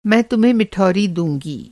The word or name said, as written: मिठौरी